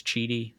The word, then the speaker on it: QIDI: And I'm messing with that new 3D printer, the Chidi, which is spelled Q-I-D-I, but everyone says Chidi. I don't know if just one person said Chidi and everyone was like, oh, that must be how you say it or what, but that's what I'm calling it.